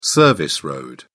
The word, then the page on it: service road: British English